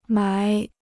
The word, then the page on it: 埋: mái